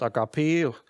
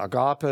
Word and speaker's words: Agape: Im Griechischen steht da ein Wort Agape oder Agape, es wird ganz unterschiedlich ausgesprochen.